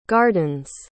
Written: gardens

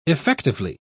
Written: effectively

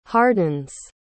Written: hardens

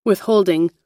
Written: withholding

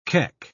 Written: keck

